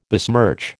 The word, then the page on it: besmirch: American English